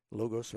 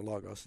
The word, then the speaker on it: Logos: Logos or Logos, it's pronounced both ways even by the programmers.